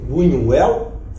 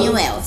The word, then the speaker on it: Buñuel: O Luiz Burinho Luiz Bunho, Buñuel, falei certo? – Sim. Falei Falou, Buñuel. Sim, como Ele tem o cão andaluz como o filme surrealista por excelência.